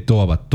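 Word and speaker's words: Torwart: Toabat. Thor es arco y arquero es Toabat.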